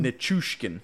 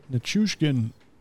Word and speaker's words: Nichushkin: That was, I can't even pronounce his name, Nichushkin? – Nichushkin. – Nichushkin? – Mm-hmm. – Nichushkin, his 13th and 1 minute 56 seconds in.